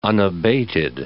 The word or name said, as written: unabated